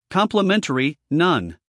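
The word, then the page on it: complimentary: uk ˌkɒmplɪˈmentri; en-us ˌkɑːmplɪˈmentri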